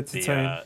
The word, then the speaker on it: Titane: Um, so I had that that duo, and then last night I watched um, Titane. Is I don't know if that's how you pronounce it. Uh, yeah, Titane.